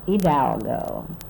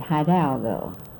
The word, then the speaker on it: Hidalgo: And the proper way to pronounce that is Hidalgo, because it was named after Father Hidalgo, a priest. But we always called it Hidalgo.